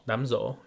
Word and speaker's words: Namzo: Is it pronounced Namzo? – Namzo, yeah.